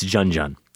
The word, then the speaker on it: Junun: So I Google J-U-N-J-U-N. I find pronunciation. And sure enough, it's Junun.